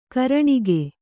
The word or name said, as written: ಘೂರ್ಣಕ